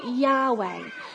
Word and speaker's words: Yahweh: But the, the best way we can say it now, the most accurate way we believe it would, be, would have been said from all the thousands of years of scholarly research is that we would say it Yahweh. That's how we would pronounce God's name today.